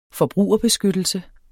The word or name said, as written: benytte